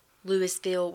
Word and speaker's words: Louisville: And just for anybody who is not from Kentucky that struggles knowing how to say Louisville, Louisville, Louisville, whatever, Louisville is how you say it. – How I say it, anyway. – It's how we say it. – Everybody that I know says it. That's how everybody says it. That's how it's correctly pronounced.